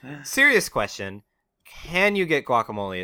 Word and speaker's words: guacamole: Wikipedia informs me that if I'm being accurate, I shouldn't pronounce the G. I should be saying guacamole.